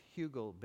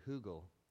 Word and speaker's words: Hügel: Joe talked about our little, he pronounced Hügel bed wrongly. It's actually Hügel bed. It's a German word.